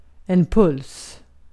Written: puls

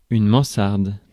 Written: garret

